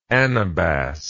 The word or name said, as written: anabas